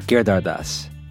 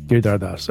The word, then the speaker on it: Giridharadas: Giridharadas. – But the name that they mostly mispronounce is the last name. – Yes. – Right? Which I got correct. – Say it again. – Giridharadas.